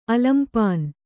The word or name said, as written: ಅಲಂಪಾನು